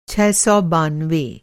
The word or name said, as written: छह सौ बानवे